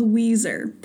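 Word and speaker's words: Weiser: I grew up in middle of nowhere, Idaho, in a little town called Weezer, pronounced like the band, spelled like Budweiser.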